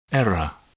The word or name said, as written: error